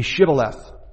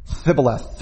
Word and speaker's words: shibboleth: And they couldn't pronounce it. Thibboleth. They couldn't pronounce that shibboleth.